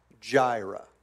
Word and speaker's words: Jireh: And we're using the J sound to pronounce that. It'd probably be an I and a Y, which is harder to pronounce Jireh with a Y.